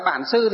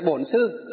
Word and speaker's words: bổn sư: Ngoài Bắc chúng ta đọc là bản sư thì trong hòa thượng toàn dịch là bổn sư.